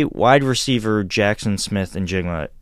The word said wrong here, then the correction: Njigba: Is that how you pronounce his name? I'm so sorry if I mispronounced Njigba. – It. Njigba. Let's say he's there in addition to Bijan Robinson.